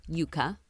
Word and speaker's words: yuca: Yuca, pronounced yuca, yeah, I was saying yucca before this experience, is the root of the cassava plant.